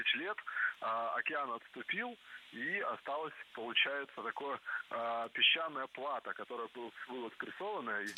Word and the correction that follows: плато: Не плата, а плато. Слово это тоже французское и тоже сохраняет ударение на последнем слоге. Причем плато – существительное неизменяемое и всегда во всех формах звучит одинаково – плато.